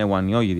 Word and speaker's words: Γουανιόγι: Ναι, Γουανιόγι διαβάζεται, ναι.